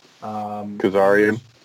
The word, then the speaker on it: Kazarian: You know, the ones that we announced so far, Darby Allin, Colt Cabana, Orange Cassidy, Rey Fenix, Scorpion Sky, Kip Sabian, Frankie, what is it, Karzian? How do you say it? – Kazarian. – And then Luchasaurus.